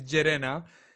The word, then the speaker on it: Llerena: And I guess my last name is LLerena, and you know, it's difficult to pronounce, I think, but that's how you pronounce it, but it's all good.